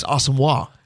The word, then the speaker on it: Asamoah: Well, and I want to talk to PA about the pronunciation of what was Brian Asamoah a year ago, but this week on PA's awesome. Show, and he joins us at 11:05, it's Asamoah.